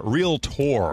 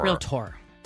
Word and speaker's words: realtor: We just had a conversation about a commercial that played a few minutes ago where they pronounced a realtor, realtor. – Realtor.